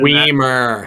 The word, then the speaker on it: Weimer: Weimer interview. I have an athlete right now who that is the reason. – Weimer. – That, Weimer. I'm sorry, Weimer. It's easy. It, it just rhymes with Wiener yeah, we, it doesn't.